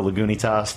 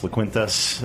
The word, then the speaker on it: Lagunitas: We're pretty used to mispronunciations, you know, Lagunitas, Laquintas, Laguntas.